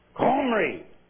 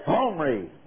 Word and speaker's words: Omri: The vowel in front was already predetermined in its pronunciation, and it was guttural. G-H or K-H is the way you pronounce it. Omri. It'd be somewhere down deep in the throat, Omri.